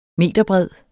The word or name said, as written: meterbred